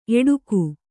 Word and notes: ಎಡಗ: eḍaga

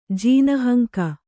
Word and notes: ಜೀನಹಂಕ: Kannada

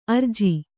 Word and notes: ಅರ್ಜಿ: Kannada

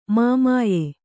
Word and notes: ಮಾಮಾಯೆ: māmāye

